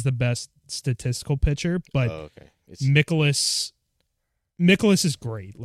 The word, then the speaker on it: Mikolas: Is it Mikolas? That's how you pronounce it? – Mikolas? Yeah, Miles Mikolas.